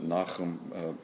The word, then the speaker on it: Nahum: And so Nahum, some would pronounce it Nahum, possibly, is the prophet here.